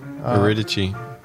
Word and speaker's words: Eurydice: and her name is I think you pronounce it Eurydice. – Eurydice. – Is that how you pronounce it? Wow, I couldn't have been more wrong. – Well, that's the Greek.